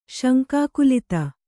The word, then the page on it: ಶಂಕಾಕುಲಿತೆ: śankākulite